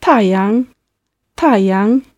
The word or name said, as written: taiyang